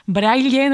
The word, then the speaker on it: braille: Y ese braille, para los oyentes que lo sepan, pues se escribe en minúscula y se pronuncia braille, ¿no? Como tú lo has dicho, con esa L, o braille. – Vale. – Pero no braille.